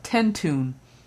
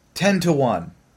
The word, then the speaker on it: Tentoone: T-E-N-T-O-O-N-E. – Now, how would you pronounce that? – Tentune. – Okay. The woman's name is 10 to 1. – Oh, God. – Like three words. The number 10, the word 2, and then the number 1.